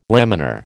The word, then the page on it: laminar: American English